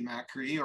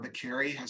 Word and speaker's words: Makary: So that is Marty Makary, Dr. Marty Makary, or Makary, as I think he pronounces it.